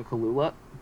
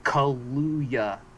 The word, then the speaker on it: Kaluuya: Like, this movie is not as violent as you want it to be, other than when they cut away to Daniel Kaluuya. Kaluuya.